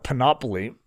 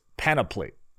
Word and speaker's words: panoply: I call it panoply, but James, who tends to be sort of a linguist guy, is always correcting me in my pronunciation. He said it's panoply. Is that right, James? Is that sound?